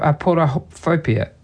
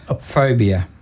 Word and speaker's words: aporophobia: Have you heard of aporophobia? Aporophobia. – How do you spell it? – A-P-O-R-O. P-H-O-B-I-A.